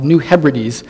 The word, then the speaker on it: New Hebrides: I think I looked that up to figure out how to pronounce it. It's called New Hebrides, a place known for its cannibalism.